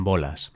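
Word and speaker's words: Bolás: Si buscamos un sinónimo de esta expresión, también funciona con quedarse en bolas. y aquí puedo contarte que bolas es mi primer apellido, Bolas con acento en la A. Pero claro, Bolas y Bolas suena muy parecido.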